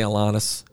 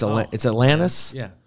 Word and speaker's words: Alanis: Well, and people always say her name wrong. I say Alanis. It's Alanis.